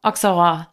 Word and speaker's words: Auxerrois: Auxerrois. – Jetzt kann ich es auch aussprechen. Auxerrois.